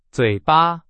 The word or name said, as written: 嘴巴